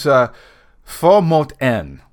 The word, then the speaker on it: Få mot en: And that was Få mot en. And I think I'm actually pronouncing that correctly. I listened to the track a couple of times, obviously, and consulted Google Translate.